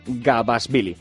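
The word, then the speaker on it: Gabashvili: Debuta hoy miércoles Rafa Nadal a partir de las 10:30 contra el ruso, a ver si lo digo bien, Gabasvili.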